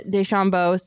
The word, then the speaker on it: DeChambeau: New customers can bet just $5 on golf's first major and get $25 in free bets for every birdie Bryson DeChambeau sinks in the first round. Did I say his name right? – Nailed DeChambeau. – It. – Yeah.